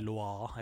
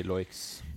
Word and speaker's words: Loix: eller Loa, eller helt hvordan du uttaler det. – Vi sier Loix.